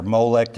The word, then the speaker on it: Molech: Some people have the theory that the pagans who sacrificed their children to Moloch, the false god Moloch or Molech, depending on how you want to pronounce it, were imitating Abraham's sacrifice of Isaac.